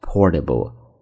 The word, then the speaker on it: portable: 那这个时候呢，我们再看第三个中间出现的这个形容词叫做 portable, portable, 但中间的 t, 美式发音发为含糊的 d, portable, portable, 怎么去读？